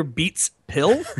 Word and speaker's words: Beats Pill: And that is my Beats pill. – I think you put the emphasis on the wrong syllable there. Did you mean your Beats pill? – My Beats pill, yes.